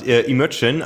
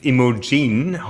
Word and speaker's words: Imogen: Also Wikipedia sagt, Imogen, alternativ Imogen, hauptsächlich in den USA, ist ein weiblicher Vorname, der vermutlich von einer Rolle aus William Shakespeares Theaterstück Cymbeline abgeleitet ist.